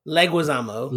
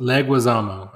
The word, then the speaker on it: Leguizamo: What if I'm going to say this guy's name wrong, but John Leguizamo. – Leguizamo. – Leguizamo. I'm sorry.